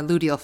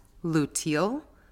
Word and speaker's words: luteal: In today's episode, we're going to turn to the inward focus of our inner waning moon phase, or our luteal phase. Now, I must add that I have heard this pronounced both as luteal and luteal. And honestly, I find myself going between the two, so just bear with me.